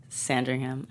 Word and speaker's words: Sandringham: Sandringham. Am I say that right? – Yeah.